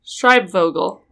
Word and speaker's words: Schreibvogel: Schreibvogel. Yeah. – It's German. – Don't try to pronounce all the letters independently.